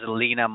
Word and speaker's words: Lene: Lene, the spelling is L-E-N-E, and then Marlin, M-A-R-L-I-N, and you pronounce it as Lena Marlin.